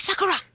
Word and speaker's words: Sakura: Well, if you want to speak Japanese, you gotta roll your R, too, but... – Okay. I pick Sakura!